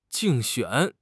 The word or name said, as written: 竞选